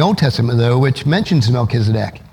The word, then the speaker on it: Melchizedek: How many of us today have trouble pronouncing Melchizedek? It's Melchizedek.